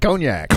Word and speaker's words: Cognac: Of course, I knew how to pronounce it. – What is it? – Cognac. – Cognac, that's right. That does sound better.